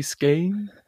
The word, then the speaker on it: Skagen: Und dann auf der Ostküste ging es dann weiter, ähm, erstmal mit Skagen, so ist der nördlichste Punkt, oder Skagen, so wie die Dänen das irgendwie aussprechen. Skagen. Ja, aber auch nicht Skagen, so wie wir, sondern irgendwie Skane.